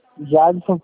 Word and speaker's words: Jadson: Jadson Prado dos Santos. – Jadson, o D é mudo? – É, Prado dos Santos.